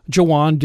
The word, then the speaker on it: Juan: where Jawan did a stand up routine and explained why his name is spelled Juan but pronounced Jawan.